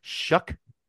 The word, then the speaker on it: Shough: But Louisville brings in Tyler Shuck, spelled not like that, but that's how you pronounce it.